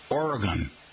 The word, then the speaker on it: Oregon: How do you pronounce O-R-E-G-O-N? Now, if you live in this state, you pronounce their home state like its last syllable rhymes with gun, Oregon.